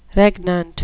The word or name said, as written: regnant